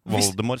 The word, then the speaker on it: Voldemort: Det er Voldemort, som det skal visst uttales uten T-en. – Ja! – På slutten der. Voldemort, så vi har sagt det feil i... i det skal rime på Oldemor.